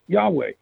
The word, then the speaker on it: Yahweh: There are four Hebrew letters, Yod, He, Waw, and He, pronounced Yahweh,